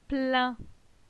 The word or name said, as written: Plin